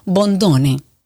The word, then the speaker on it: Bondone: Así se pronuncia, Martín, porque nunca supe. Bondone. – Sí, sí. Bondone, sí, es italiano.